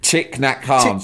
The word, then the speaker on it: Thich Nhat Hanh: Thich Nhat Hanh. So you spell it T-H-I-C-H. – Yeah. I'm on his Wikipedia. – N-H-A-T and then Han, H-A-N-H.